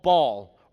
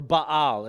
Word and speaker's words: Baal: And so the people of Israel, God's chosen people, begin to worship an idol, a false god called Baal, or Baal is how you would pronounce it.